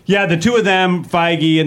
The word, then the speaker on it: Feige: Feige? – Yeah, Feige.